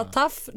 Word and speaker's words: TAUFF: Det är ju T-A-U-F-F som taff.